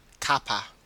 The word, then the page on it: cuppa: Australian English